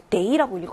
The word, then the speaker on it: 得: 여기서 데이라고 읽어야겠죠? 뭐뭐 해야 한다라는 조동사 용법입니다.